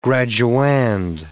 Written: graduand

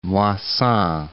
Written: Moissan

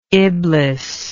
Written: iblis

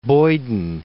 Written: Boyden

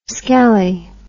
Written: skelly

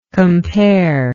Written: comparer

